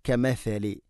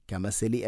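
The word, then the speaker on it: കെമെഫെലി (كمثل): കെമെഫെലി നാവിൻ്റെ അറ്റം മുൻപല്ലുകളുടെ ഇരുനിരകൾക്കിടയിലൂടെ അല്പം പുറത്തോട്ട് വരണം. കെമെഫെലി, കെമെസെലി അല്ല, കെമെഫെലി ലദീന, അല്ലദീന, ലസീന അല്ല, അല്ലദീന.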